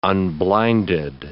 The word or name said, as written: unblinded